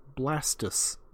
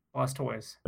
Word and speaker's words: Blastoise: Are you going to tell me that, like, Blastoise is actually pronounced Blastus because it's Tortoise plus Blast or something like that? – No. Blastoise.